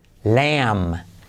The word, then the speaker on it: lamb: Cordero, lamb. Lamb. La B no se pronuncia.